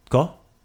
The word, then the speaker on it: tko: The word we're gonna ask, ko? So that T, it kind of just like flows, ko? – So do I have to pronounce? – So you don't say tko, you just say tko?